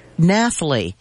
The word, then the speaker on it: Nathalie: Nathalie. – N-A-T-H-A-L-I-E.